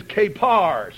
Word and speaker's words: kapar: The word pronounced in English is kapar, C-A-P-A-R.